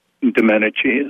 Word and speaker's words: Domenici: That was Senator Domenici, is it pronounced? – Domenici is the right pronunciation, yes.